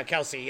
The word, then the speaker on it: Kelce: I don't know how to pronounce his name right, but Kelce. – Yeah. – Kelce. – Yep. – Kelce. – Yeah, Kelce.